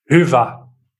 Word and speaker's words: Hyvä: So what is the real pronunciation? Here we go. – Hufa. – Ah. Hufa. And that's Swedish? – It's Swahili? Finnish. – Ah, it's Finnish.